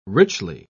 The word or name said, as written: richly